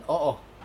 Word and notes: oo: Aneityum; trad

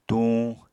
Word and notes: tuñ: apatani

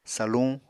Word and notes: saluñ: Apatani

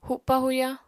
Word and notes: hupa-huya: apatani

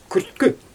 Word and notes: kürtkü: Xyzyl

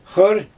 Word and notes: xɯr: Xyzyl

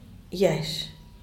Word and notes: yash: diaz_ordaz

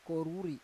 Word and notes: koruri: Roon

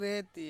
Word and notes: reti: Ansus

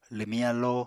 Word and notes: lwmiñ alló: apatani

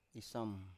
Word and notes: isam: Biak

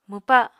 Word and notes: mwpa: Apatani

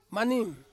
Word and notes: maning: Ansus

